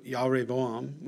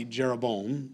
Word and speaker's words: Jeroboam: Then Solomon's servant, Jeroboam, and if you want to pronounce it the right way in the Hebrew, it would be Yahreboam would be how you'd say it in Hebrew. We would say Jeroboam, lo.